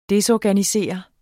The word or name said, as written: DESORGANISERE